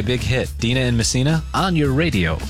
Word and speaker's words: Messina: Is that how you pronounce it? Messina? – Yeah.